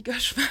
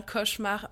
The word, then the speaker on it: cauchemar: Moi, je dis gauchemar, mais c'est vraiment mon accent. – Ok. – Sinon, ça s'écrit bien avec un C. – Ok. Je me suis demandé s'il y avait un jeu là-dessus. – Non, avec le fait d'être de gauche, je pense... Enfin, elle parle aussi du fait d'être de gauche plus loin, mais non, c'est bien cauchemar, mais vous étonnez pas si je prononce gauchemar.